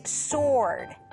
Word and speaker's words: sword: 네, and the third word is sword. Sword. – 네, SW지만 W 친구들 발음이 안 납니다. – Sword이야. Sword가 아니고요. Sword. 나 검입니다.